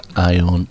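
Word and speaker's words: Ayon: Ion, that's A-Y-O-N, but pronounced Ion, End of My Rope.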